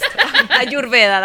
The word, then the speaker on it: Ayurveda: Ayurveda, that's how you pronounce it in Spanish.